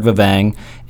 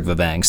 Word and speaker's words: Vevang: Yeah, two places to go is LinkedIn, Eric Vevang, and Instagram, Eric Vevang. So last name is spelled V-E-V-A-N-G. So Eric, E-R-I-C, Vevang, V-E-V-A-N-G.